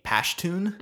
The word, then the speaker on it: Pashtun: And he is a Pashtun. – Mm-hmm. – Is that how you pronounce it? – Yeah.